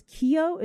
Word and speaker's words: Keough: And speaking of that main actress, Riley, and I think it's Keough is how you say the last name.